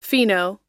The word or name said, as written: fino